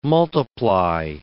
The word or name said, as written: multiply